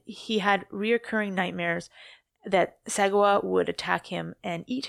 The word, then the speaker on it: Sagawa: I always call him Sagawa. – No, it sounds right. – I think so. It's S-A-G-A-W-A. Sagawa? Yeah. Okay.